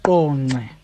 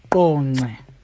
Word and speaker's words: Qonce: King Williamstown is now Qonce. Qonce. I'll say it again. Qonce.